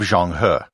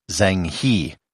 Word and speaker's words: Zheng He: And here I should note the pronunciation of Zheng He. If you read the title of this episode and you speak English, you might pronounce it as Zhang He. I'm trying to stay true to the Chinese pronunciation as much as I can.